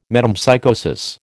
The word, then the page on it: metempsychosis: American English